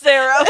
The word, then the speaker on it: Serra: Feel free to use my name, Sarah, but it's spelled S-E-R-R-A.